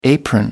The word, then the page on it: apron: American English